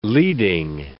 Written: leading

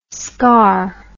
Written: scaur

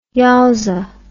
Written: yowza

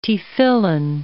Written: tefillin

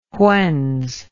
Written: whens

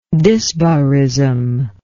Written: dysbarism

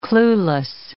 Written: clueless

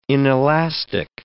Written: inelastic

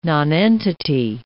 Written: nonentity

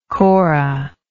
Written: Cora

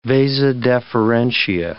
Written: vasa deferentia

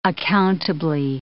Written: accountably

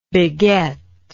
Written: begetter

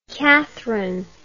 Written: cathryn

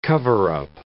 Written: cover-up

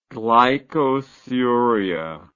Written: glycosuric

